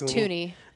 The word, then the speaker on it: Thuney: Is it Thuney? – I think it's Thuney. I said that more confidently than I am, though. – I think you're right.